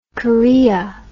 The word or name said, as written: corea